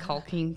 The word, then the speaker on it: caulking: I have a lot of caulking to do. – Thank you for properly pronouncing that L.